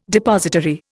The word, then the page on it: depository: Indian